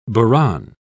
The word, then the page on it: buran: British English